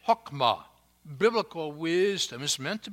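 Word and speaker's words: hokmah (חכמה): We would pronounce the Hebrew word "hokmah."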